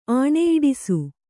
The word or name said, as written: ಆಣೆಯಿಡಿಸು